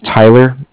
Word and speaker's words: Tyler: Okay, my name is Tyler, T-Y-L-E-R, and I'm calling with Cardiff. It's spelled C-A-R-D-I-F-F, as in Frank.